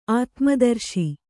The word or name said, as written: ಆತ್ಮದರ್ಶಿ